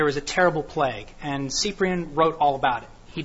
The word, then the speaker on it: Cyprian: So the example I thought of was St. Cyprian, or Cyprian, however you want to pronounce it. I say Cyprian.